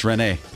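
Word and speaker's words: René: He's French, Rene.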